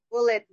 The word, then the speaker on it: Bullet: Bullet, BULLET, like a Huh? – bullet.